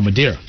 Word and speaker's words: Madeira: Uh, they have was it Madeira? I always get them. I always say that wrong. Medina? No, Madeira. Madeira.